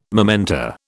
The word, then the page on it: momenta: British English